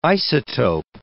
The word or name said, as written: isotope